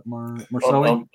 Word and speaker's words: Marseille: Marseille.